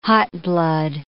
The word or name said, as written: hotblood